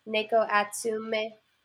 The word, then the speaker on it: Neko Atsume: And I believe it's pronounced, Liz, you can correct me if I'm wrong, is it Neko Atsume? Is that Neko Atsura. – Neko Atsume. What Liz said.